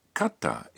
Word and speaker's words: かった: Now, katta is pronounced with a brief pause in between ka and ta.